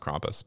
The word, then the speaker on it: Krampus: Some people say Krampus. – Yeah. – I believe it is pronounced Krampus, but, you know, send me your letters.